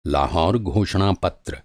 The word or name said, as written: घोषणापत्र